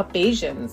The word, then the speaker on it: Bajans: Ja, also je nachdem, Barbadianer sagen wir natürlich auf Deutsch, und vor Ort auf die Insel nennen sich selber Bajans, also Bajans. – Bajans. – Bajans. Da schreibt man B-A-J-A-N, ja.